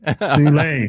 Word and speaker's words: Soule: Soule.